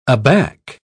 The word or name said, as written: aback